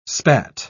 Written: spat

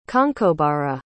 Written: conchobara

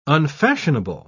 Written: unfashionable

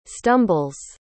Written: stumbles

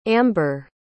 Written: ambre

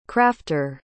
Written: crafter

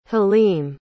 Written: halim

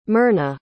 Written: Myrna

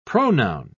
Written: pronoun

